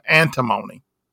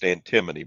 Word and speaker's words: antimony: Cooper's tossed tie pinpoint the skyjacker's workplace to a Midland, Pennsylvania-based manufacturer of titanium antimony. – Okay. – And then all he needs now, is the samples tie, which is why he's pursuing litigation to procure them. – That's pronounced antimony, by the way.